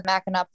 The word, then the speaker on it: Mackinac: There's sometimes some confusion about the pronunciation, even though the name of Mackinac Point is spelled MACKINAC. It's pronounced Mackinac.